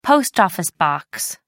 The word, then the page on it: post-office box: British English